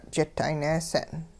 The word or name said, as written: jit 'aayneese'n